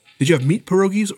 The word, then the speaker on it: pierogies: Well, it's just like I've never had pierogies till I was out east. – Pierogies? – Is that how you say it? Am I saying it wrong? – No, that's right.